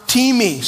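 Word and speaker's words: timi (τιμή): Honor is the Greek word timi, spelled like time, T-I-M-E.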